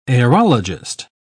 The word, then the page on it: aerologist: British English